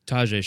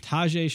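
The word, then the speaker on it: Tajaé: But Tajay Sharp or Taj Sharp? – Tajay Sharp. – Tajay Sharp. – So see, I feel like I'm putting the emphasis on the Ta and I shouldn't.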